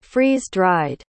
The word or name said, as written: freeze-dried